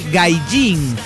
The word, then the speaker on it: Gaijin: La H suena como J, Yokohama, ese Hama se escribe con H pero es con J, se dice con J, Hokkaido, y la J suena como Y, es decir, Gaijin, Romaji o Meiji.